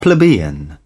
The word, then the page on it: plebeian: British English